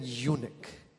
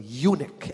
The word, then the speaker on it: eunuch: He was talking about the story, his autobiography, Philip and the Ethiopian eunuch. Let me say that correctly. Eunuch. Everybody say eunuch.